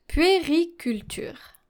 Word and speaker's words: puériculture: Puériculture is a feminine noun and it comes from the Latin term puer, P-U-E-R, puer, which means child, and then culture, puériculture. I know this word sounds a bit challenging to pronounce because you have the sound U and E that are following one another, but if you want a technique for this, just make sure to articulate.